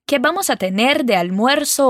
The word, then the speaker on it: almuerzo: Almuerzo. Bom, essa é uma palavrinha que alguns alunos sentem um pouquinho de dificuldade de pronunciar. Vamos por partes então. Al. Muer-so. Juntando tudo agora, almuerzo.